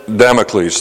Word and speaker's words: Damocles: a Damascus sword over our head. In Damocles, thank you. I said Damascus, Damocles sword.